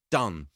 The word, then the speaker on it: Donne: Is it because of Van Morrison's terrible rhyme, Rave on, John Donne? – It is.